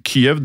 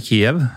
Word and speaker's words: Kyiv: Um, og nå er det vel sånn at man skal Kyiv, det er da den ukrainske uttalen, er det det? Ja. – Eh, man er jo vant til å si Kiev, ja. eh, som da er det russiske navnet på byen.